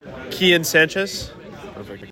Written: Kian Sanchez

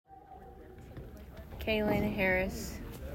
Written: Kalyn Harris

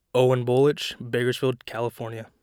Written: Owen Bolich

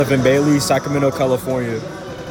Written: Donovan Bailey

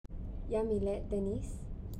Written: Yamilet Deniz

